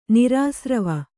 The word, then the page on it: ನಿರಾಸ್ರವ: nirāsrava